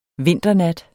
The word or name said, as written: vinternat